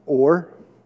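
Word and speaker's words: or (אור): So the word for light uh, for Hebrew, it's pronounced or, and this is spelt aleph, vav, and resh.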